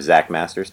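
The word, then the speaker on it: Xakkmaster: And on there, I'm Zach Master, spelled with an X, X-A-K-K-M-A-S-T-E-R.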